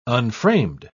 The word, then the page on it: unframed: British English